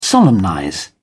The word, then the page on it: solemnize: British English